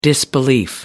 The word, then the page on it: disbelief: American English